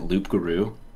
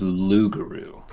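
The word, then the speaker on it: loup-garou: Did D&D Beyond pronounce it Loop Guru? – I don't think so. No, that's just a special. – I think it's Luguru. – Probably is. – For using the French pronunciation.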